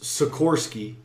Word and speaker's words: Sikorsky: But, But, like, Vaughn's Sikorsky is how you say his name, right?